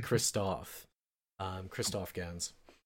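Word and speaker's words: Christophe Gans: And again, this comes back to one of our very original points, and it's that the director, and I believe I've mispronounced his name several times, it's actually Christoph Gans.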